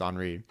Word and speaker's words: Henri: It's why it's spelled like Henry, but it's Henri.